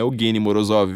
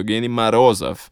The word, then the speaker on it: Evgeny Morozov: Eles vão lá e falam assim, ó, não é Evgeny Morozov, viu? É Evgeny Marozov. Então vamos lá falar, né? Evgeny Marozov mas se escreve Morozov.